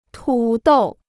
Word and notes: 土豆: tǔ dòu

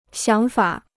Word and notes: 想法: xiǎng fǎ

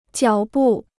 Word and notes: 脚步: jiǎo bù

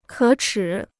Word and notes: 可耻: kě chǐ

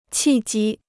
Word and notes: 契机: qì jī

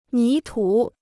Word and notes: 泥土: ní tǔ

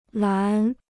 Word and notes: 栏: lán

